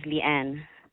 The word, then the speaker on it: Leann: Yes, it's my email is Leanne. – Okay. – L-E-A-N-N. – L-E-A-N-N.